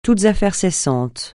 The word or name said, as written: toutes affaires cessantes